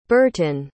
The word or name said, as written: Bertin